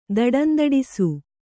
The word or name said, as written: ದಡಂದಡಿಸು